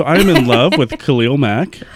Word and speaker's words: Khalil Mack: I would say that I am in love with Khalil Mack.